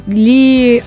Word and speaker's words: gli: Ли Анни Пьюбелли. А первая G не читается.